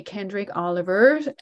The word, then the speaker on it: Kendrick Oliver: Frustration, a recording from the Welcome to New Life album by my guest today, Kendrick Oliver, and the the New Life Jazz Orchestra.